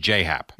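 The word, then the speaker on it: J.A. Happ: Right, but he wants it pronounced J-Hap.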